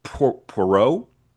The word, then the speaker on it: Poirot: Hercule Poirot, did I say it right? – Yeah, And Miss.